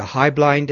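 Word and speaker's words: Heublein: In Hartford, the Hyblein Distillery, that's how we pronounce Hublein around here, the Hyblein Distillery and Royal Typewriter are long gone.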